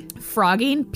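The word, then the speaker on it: phrogging: Frogging, P-H-R-O-G-G-I-N, but it's pronounced like frogging, like frogs.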